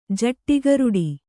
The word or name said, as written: ಜಟ್ಟಿಗರುಡಿ